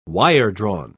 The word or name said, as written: wiredrawn